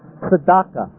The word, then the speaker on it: tzedakah: D-A-K-A-H. The reason the E is written above the line is because it's a half vowel. It's only there so you can pronounce T-Z-D. Otherwise, without a vowel, there's no way you could pronounce T-Z-D. Your mouth couldn't get around it. And so they stick in a half vowel to make it pronounceable. Tzedakah. Tzedakah. You pronounce it as if you're trying to say them without a vowel, and you barely hear the vowel.